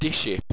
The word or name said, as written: disship